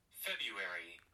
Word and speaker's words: February: February. – Oh, interesting. – Wow. – So why would they put a second R in it if you're not supposed to say it? – It's a silent R, apparently.